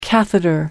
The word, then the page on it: catheter: American English